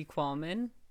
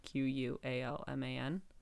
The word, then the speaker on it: Qualman: At Katie Qualman. My last name is spelled Qualman.